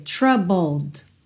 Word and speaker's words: troubled: Atenção aí, como o E de troubled não é pronunciado, você tem o BL, bull, bull e depois o D, troubled, troubled.